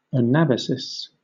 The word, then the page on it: anabasis: British English